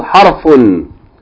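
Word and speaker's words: harfun (حرف): Letter of the alphabet is called harfun. Harf with sukoon on the ra.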